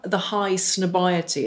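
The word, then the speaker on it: Highsnobiety: Can you tell us the high snobiety, is that how you pronounce it? – Yeah, story.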